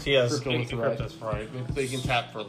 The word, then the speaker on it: Cryptolith: Cryptolith. – Cryptolith.